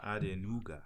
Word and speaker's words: Adenuga: It's Mike and A-D-E-N-U-G-A. Andenuga. – Andenuga.